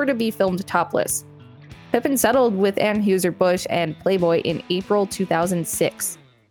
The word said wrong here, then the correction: Anheuser-Busch: Uh, it's Anheuser-Busch. I know it's spelled totally different, but that's what it is.